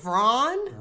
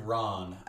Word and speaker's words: Ran: Speaking of young, I'm going with a movie from 1985 called Ron. R-A-N. Also... – Vron? – Ron. Oh. R-A-N.